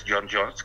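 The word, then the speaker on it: J'onn J'onzz: Y el otro es John Jones, que efectivamente así se pronuncia.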